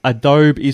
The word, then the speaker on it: Adobe: For the record, his pronunciation, Adobe, is completely incorrect.